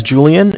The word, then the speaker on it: Julian: All right, my name is Julian. It's J-U-L-I-A-N. – J-U-L-I-A-N, okay.